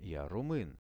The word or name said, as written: ja rumyn